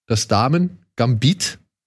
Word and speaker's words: Das Damengambit: Sie heißt Das Damen-Gambit. So wird es richtig ausgesprochen auf Deutsch, ne? – Schon, ja.